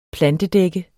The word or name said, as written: plantedække